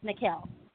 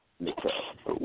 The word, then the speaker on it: Nikkel: So is it Nikkel? Is that the correct way to say it? – Yes, Nikkel. – Nikkel. With an N like Nancy or M Mary? – Yes, with an N like Nancy.